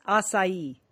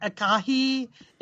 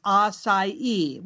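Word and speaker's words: açaí: Some people, it's not acai. It's not acai. It's not acai. It is acai with a soft C and stress on the last syllable.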